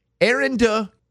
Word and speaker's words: Arinda: Oddity Central says Arinda Weaver of Ohio. It's an odd name. A-R-I-N-D-A. Arinda? That's how you would pronounce it, right? A-R-I-N-D-A. – Yes.